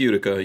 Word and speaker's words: Utica: Utica, I think it's Utica. How you pronounce it? Mm-hmm. The knife company in Utica, New York, had leftover bayonet stuff. – Yeah, it's Utica. Yeah.